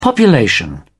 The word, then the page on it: population: British English